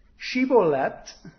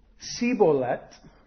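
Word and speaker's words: shibboleth: And if he said no, then he would say to them, to him, verse 6, they would say this, 'Then say, Shibolet.' And he would say, 'Sibolet,' because he could not pronounce it right.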